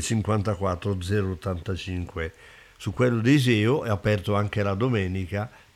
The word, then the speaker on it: Iseo: Uno in via Campo 72 a Iseo, bisogna pronunciarlo bene, Iseo,